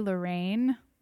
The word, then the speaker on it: Lorraine: You can follow me on Instagram and other social media things at Hooray Lorraine, which I apologize. Lorraine is my middle name, so it's spelled L-O-R-R-A-I-N-E, mm-hmm. – and Hooray is H-O-O-R-A-Y.